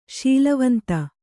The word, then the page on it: ಶೀಲವಂತ: śilavanta